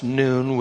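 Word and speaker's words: noon (ن): Now, I, I showed you last week, Daryl put up on the screen there the letter N, which is pronounced noon, which is what was used in 2014 in Mosul for the Christians.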